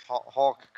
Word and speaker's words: Hulk: I thought you said Hawk. – I say that a lot. I don't pronounce Hulk correctly. – Hulk. Just say it like that. Hulk.